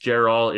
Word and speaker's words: Jeral: I believe it's pronounced Gerall. It's spelled J-E-R-A-L.